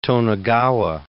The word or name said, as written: Tonegawa